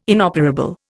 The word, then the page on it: inoperable: Indian